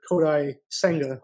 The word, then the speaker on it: Kodai Senga: Maybe they have, maybe they do make an addition, because obviously they're, I think they're involved with that Japanese starter, Kodai Senga.